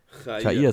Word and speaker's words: Chair: Chair, ist besser, Chair. oder? Chair. Chair. Ja, jetzt haben wir es. Jetzt haben wir es besser.